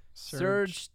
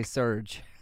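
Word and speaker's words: Serj: I think it's Serg. – Serg? – Not Serge. – I was going to say Serge. Serge. – Serge. No, Serg.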